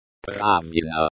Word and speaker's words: Правильно: Say правильно with a slight emphasis on the first syllable.